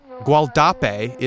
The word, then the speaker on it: Gualdape: The Gualdape, I believe it seems like eventually became... – Guadalupe? – Yes. – Yeah. – Gualdape is the Spanish pronunciation, but it's presumably named after the local Wale tribe, is the pronunciation that I've seen historians say.